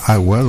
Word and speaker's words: AUA: Vamos a pasar ahora con este grupo que se llama Agua, bueno, A-U-A, pero se pronuncia Agua.